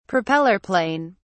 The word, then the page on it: propeller plane: British English